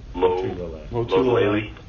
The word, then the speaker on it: Lotulelei: Lotulelei.